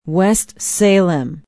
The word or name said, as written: west salem